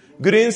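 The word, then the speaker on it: Grün: Green se escribe G-R-U-N, pero esa U va con diéresis. Es la Green, la U que tienen los alemanes, la U con diéresis.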